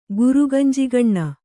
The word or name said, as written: ಗುರುಗಂಜಿಗಣ್ಣ